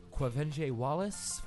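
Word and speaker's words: Quvenzhané Wallace: Quavenge Wallace for Beasts of the Southern Wild.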